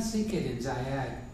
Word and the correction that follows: Zayd: First, the pronunciation is Zayd and Amr.